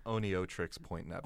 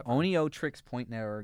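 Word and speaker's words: Oneohtrix Point Never: I always mispronounce like this band name. Owen Trix Point Never. – I think it's Oneo Trix Point Never. – Oneo Trix Point Never, Garden of Delete.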